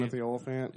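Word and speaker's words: Timothy Olyphant: Timothy Oliphant.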